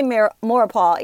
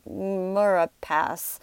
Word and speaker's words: Maurepas: He couldn't say Maurepas either. He said like Murapass.